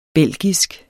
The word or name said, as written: belgisk